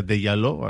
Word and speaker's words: Djaló: porque nos decía un oyente que se pronuncia de Yalo así que bueno, le voy a hacer un poquito de caso.